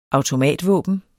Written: automatvåben

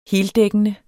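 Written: heldækkende

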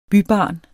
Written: bybarn

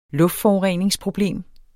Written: luftforureningsproblem

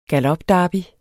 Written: galopderby

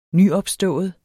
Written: nyopstået

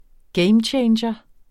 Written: gamechanger

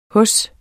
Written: hos

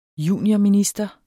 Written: juniorminister

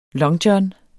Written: longjohn

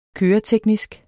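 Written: køreteknisk

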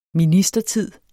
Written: ministertid

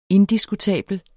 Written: indiskutabel

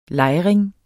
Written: lejring

